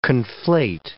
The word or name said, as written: conflate